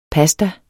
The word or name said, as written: PASTAERNE